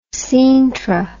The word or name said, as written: Sintra